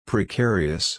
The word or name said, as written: precarious